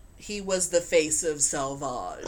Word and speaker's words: Sauvage: I believe it would have been pronounced Sauvage, because it was Savage, but with a U in it.